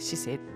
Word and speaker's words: 市政: に政治のせって書いて市政って読むんですけども、